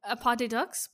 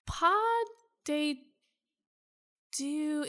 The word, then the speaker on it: Pas de Deux: From November of this year, and they have listed Pieces of Me and Pas de Deux, Pas de Ducks? Pas de, how do you fucking pronounce it? – Pas de Deux, you're right.